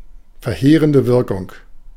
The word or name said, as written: verheerende Wirkung